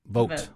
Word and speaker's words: Vought: Vought, I think, is how you say it. – Vought.